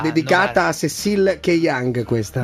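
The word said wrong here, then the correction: Kyenge: No, Kieng. Kieng. Tu non sai nemmeno Keyang. La pronuncia, ma quale...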